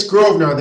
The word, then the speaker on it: Grosvenor: It's Grosvenor. The S is silent.